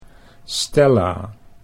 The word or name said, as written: Stella